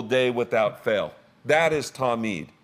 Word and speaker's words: talmid: So the first Hebrew word is the word talmid. Talmid. If you're taking notes, it is spelled T-A-L-M-I-D.